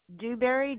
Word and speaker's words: Dewberry: Dewberry, D as in dog, E-W, mm-hmm. – B as in boy. – R-R-Y.